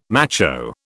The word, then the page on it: macho: British English